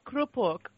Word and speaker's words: krupuk: There are certain things that are kind of universal, starting with the fact that, what do you call, how do you pronounce karupu? You always have a savory starter. – Oh, krupuk.